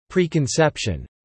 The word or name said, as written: preconception